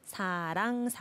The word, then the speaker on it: 사랑: This one, not wrong or lang, like this. 사랑.